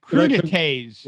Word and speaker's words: crudités: It looks like crudités. – Would you like some crudité? – Is the S silent? Crudité? Is that plural yet with a silent S? – I believe so.